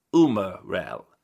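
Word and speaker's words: umarell: But if not, umarel, Italian pronunciation, umarel,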